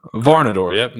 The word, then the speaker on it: Varnadore: And am I saying that right, Seth? Is it Varnadore? – Varnadore, yep, nailed it.